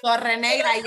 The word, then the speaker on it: Torrenegra: Torrenegra, yes. Okay. Yeah, you said it correctly. – Spanish, just to let you know.